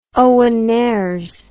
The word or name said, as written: Owen Nares